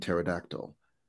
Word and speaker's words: pterodactyl: He decides to rid the world of all of those annoying silent letters, like the P in pterodactyl, and builds a machine to do just that.